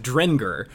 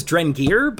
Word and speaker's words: Drengir: it's pronounced Drengar. I thought it was Drengir, but then I heard the audiobook, and or two different audiobooks, and they both were pronouncing it Drengar.